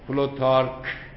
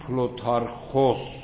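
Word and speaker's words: پلوتارخوس (Πλούταρχος): آدم دوم، آدمی که در اینجا خوبه اسمش رو شما بشناسید، کسی است که در کتاب‌های انگلیسی می‌نویسن پلوتارک. خب این تلفظ انگلیسیه و ربطی به اسم این آدم و ما ندارد، ما باید بگیم پلوتارخوس،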